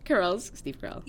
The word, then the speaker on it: Carell: Because, like, Michael's character, like, Steve Carell.